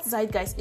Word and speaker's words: Zeitgeist: Zurgest. It means the spirit of the age. So this is how it's pronounced on Google. – Zeitgeist. – Okay, so it's called Zeitgeist. It's the spirit of the age.